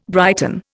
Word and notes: brighten: Indian

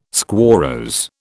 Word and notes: squarrose: British English

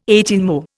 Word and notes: eighteenmo: Indian

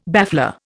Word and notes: baffler: Indian

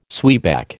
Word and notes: zwieback: American English

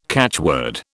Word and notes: catchword: British English

